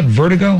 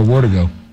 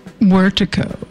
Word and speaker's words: Wortigo: Is that Vertigo or Wortigo? – It's Wortigo. – Vertigo, is that something you Wortigo. – Get when you're walking in circles? Vertigo, Wortigo, tomato, tomato. It's Wortico. It's with a W.